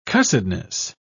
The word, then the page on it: cussedness: British English